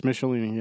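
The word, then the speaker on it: Michelinie: Nan Wang worked for a law firm called Michelini and McFarlane. Did I say the name right? – It's Michelini, yeah.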